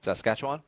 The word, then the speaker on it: Saskatchewan: How did you end up crashing into the only tree at, I don't even know how to say this word, Saskatchewan? – Saskatchewan? – There you go.